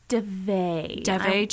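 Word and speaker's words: Daveigh: Before I talk about what happened with, how do you pronounce her name? The actress who played Samara? – I think it's DeVay Chase? Yes.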